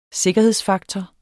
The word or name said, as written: sikkerhedsfaktor